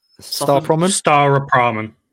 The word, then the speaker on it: Staropramen: Staropramen.